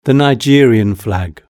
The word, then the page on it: the nigerian flag: British English